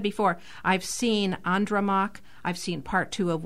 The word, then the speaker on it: Andromaque: It's dramatized by the French playwright in the 17th century, French playwright Jean Racine, hence the French pronunciation of Andromaque.